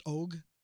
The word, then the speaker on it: Ogg: Stephen Ogg, which, by the way, I've heard it pronounced Og, but it's Og. – I was going to say, I've only heard Og, right? Okay. – I've heard bloggers refer to him as Stephen Og. – Okay. – But it's Og. – Well, you're wrong, bloggers. – Yeah. By the way, Og rhymes, I wrote this down so I wouldn't forget. Og rhymes with frog and log.